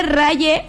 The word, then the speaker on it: Raye: Se escribe Raye, se pronuncia como Ray.